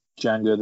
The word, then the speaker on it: Django: Asking him to spell his name, Django the D is silent.